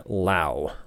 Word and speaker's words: Lau: Let's go to Baseball Reference and check out if he's a Lau or a Low. Brandon is now, so it's Brandon Lau. I love that it says rhymes with now, and his nickname is Bam Bam.